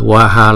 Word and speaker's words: Swaha: Rồi chẳng hạn như một cái nữa là ở dưới đây nè, Swaha, nếu mà chúng ta ghi là sờ, Swaha là thành ba âm đúng không? Nhưng mà chúng ta chỉ đọc là sờ, chỉ xịt gió thôi chứ không có đọc chữ ờ vào đó.